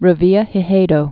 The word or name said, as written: Revillagigedo Islands